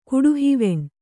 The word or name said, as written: ಕುಡುಹಿವೆಣ್